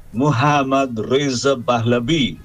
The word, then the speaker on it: Mohammad Reza Pahlavi: Y a partir de que yo tomé contacto con el traductor de la selección de Irán, empecé a decir en la radio Mohammad Reza Pahlavi. Esa es la forma en que se pronuncia.